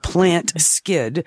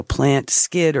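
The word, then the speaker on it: Plantskydd: It's spelled plant, P-L-A-N-T, and it's all one word, S-K, YDD. So plant skid or skide.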